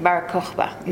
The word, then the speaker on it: Bar Kokhba: Isn't it true, too, because we almost made a big mistake with, I'm going to say his name wrong, Bar Kokhba? – Bar Kokhba.